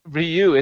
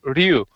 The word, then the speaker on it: Ryu: Not, the thing is, it's not even Ryu. It's one single syllable. It's Ryu. – Oh, you want to roll your R's now? Now you want to be Spanish. Now you want to be Spanish. – It is one syllable. It's not a two syllable word to begin with.